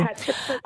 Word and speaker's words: Hatshepsut: Hatshepsut. I always say her name wrong, so don't worry. It's Hatshepsut.